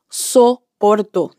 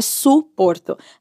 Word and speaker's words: soporto: A gente diz soporto, não é suporto.